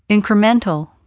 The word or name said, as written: incremental